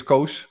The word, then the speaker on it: Koos: So you did pronounce it right, and that's that doesn't happen often with English-speaking people. Yeah. So my name is Koos Goossens from the Netherlands.